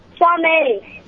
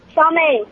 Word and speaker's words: Somya: My name is Shamei, and the answer is mature. – And tell me your name again. – Shamei. – Spell it. – Shamei. – S-O-M-E-N? – No, S-O-M-Y-A.